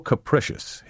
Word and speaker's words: capricious: Note the difference in pronunciation between capricious and caprice. Don't say capricious. Capricious should rhyme with delicious.